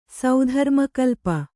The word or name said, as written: ಸೌಧರ್ಮಕಲ್ಪ